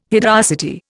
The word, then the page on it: hideosity: Indian